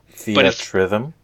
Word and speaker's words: Theatrhythm: Theatrhythm. – Yeah, it's like theater rhythm and mash those two words together.